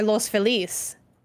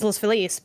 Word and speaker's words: Los Feliz: It's like, is it Los Feliz or Los Feliz? Mm. If you say it in Spanish, it's Los Feliz.